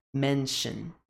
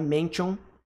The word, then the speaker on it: mention: Sempre quando a gente tem esse T-I-O-N, T-I-O-N, no fim de uma palavra em inglês, não é tion, tá? Não é mention. Não é mention. É mention. Esse T-I-O-N tem som de shen, shen, shen, shen, shen.